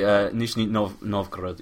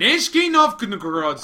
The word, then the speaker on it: Nizhny Novgorod: Nizhny Novgorod. Yeah, well, Novgorod. – Nizhny Novgorod.